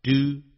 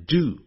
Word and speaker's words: due: D-U-E 啊， due 加一个 to，我一再讲美式英语念成 do 啊，英式英语念成 due 啊， D-U-E due to the accident，或者也可以用 owing to the accident，这些都要记起来哦， O-W-I-N-G O-W-I-N-G owing to the accident， grandmother will forbid my brother and me me 跟 from 分开啊，先把它分开。